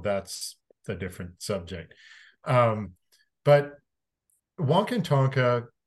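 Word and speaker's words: Wakan Tanka: It's pronounced Wonkintonka.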